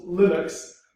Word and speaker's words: Linux: Like, I'm Linux Torvalds, I'm the inventor of Linux or something like that, and I pronounce it Linux, because lots of people confused it and didn't know how to pronounce it properly,